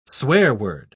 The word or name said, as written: swearword